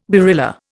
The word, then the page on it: barilla: Indian